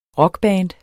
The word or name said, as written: rockband